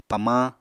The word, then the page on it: pamañ: Apatani